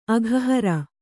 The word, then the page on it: ಅಘಹಾರಿ: aghahāri